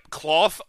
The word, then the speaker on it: Klauth: Yep, Cloth Unrivaled Ancient, and it's spelled K-L-A-U-T-H.